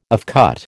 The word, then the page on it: offcut: American English